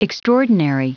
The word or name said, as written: extraordinary